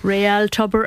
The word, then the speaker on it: Tubber: And in the Connacht Cup under 12, it looks like Real Tubber are the real deal. – Real Tubber are the real deal. You said it correctly.